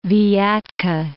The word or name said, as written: Vyatka